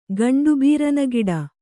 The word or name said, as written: ಗಂಡುಬೀರನಗಿಡ